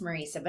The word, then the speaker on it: Marisa: I'm gonna let you, did I say that right? – It's Marisa, but everybody does it.